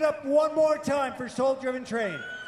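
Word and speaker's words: Sol Driven Train: Right here on KVNF Live, Soul Driven Train. If you're looking for them online, that's spelled S-O-L, as in solar or the sun.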